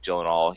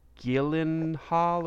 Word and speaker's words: Gyllenhaal: I think it's pronounced like Gyllenhaal or something. Everybody pronounces it wrong.